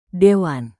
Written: dewan

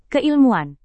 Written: keilmuan

